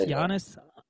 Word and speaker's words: Giannis: It's Giannis like Yanni. So it's Giannis Antetokounmpo.